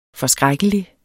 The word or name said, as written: forskrækkelig